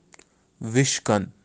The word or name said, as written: vishkan